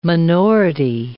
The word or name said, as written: minority